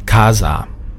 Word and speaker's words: casa: Es hört sich für die Spanier fürchterlich an, wenn Sie sagen Casa. Ja, das S, das weiche S, das gibt's im Spanischen nämlich nicht. Sie müssen immer das S immer scharf aussprechen.